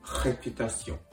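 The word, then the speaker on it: réputation: へという形で、ヘピタシオン、ヘピタシオンと発音してあげるところがポイントです。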